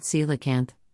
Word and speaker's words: coelacanth: Scientists thought coelacanth, pronounced coelacanth, became extinct with the dinosaurs 65 million years ago, but in 1938 one was caught in the West Indian Ocean near South Africa.